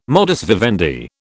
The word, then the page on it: modus vivendi: British English